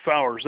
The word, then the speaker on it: Fowers: You say your last name is Fowler. – Yeah, it would be F O W E R S.